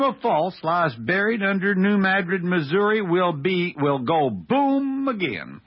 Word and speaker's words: New Madrid: He says the New Madrid quakes, and by the way, I'm pronouncing New Madrid properly for those of you who speak Spanish. It looks like New Madrid, but in Missouri, we talk funny. It's New Madrid.